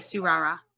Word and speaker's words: Tsurara: Right, Sarara is T-S-U-R-A-R-A. I'm guessing that's just because the T's in Japanese, I believe, are usually silent. – Spell it again. – T-S-U-R-A-R-A. Because I know, like, Suyu Asui from My Hero Academia has a T. – Yeah, the T's silent. So I think you're right. Like, Surara, I believe, is how I would say it.